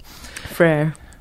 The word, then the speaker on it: Freire: Freire. – Freire? – My brother in French, but it's Portuguese.